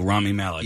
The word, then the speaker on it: Rami Malek: Rami Malek.